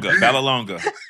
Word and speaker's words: Vallelonga: It's Nick Vallelonega. Vallelonega. Vallelonga. Oh, God. Okay. I knew I was going to tank it. Vallelonga.